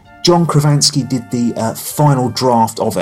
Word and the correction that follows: John Krasinski: John Krasinski.